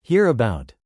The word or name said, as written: hear about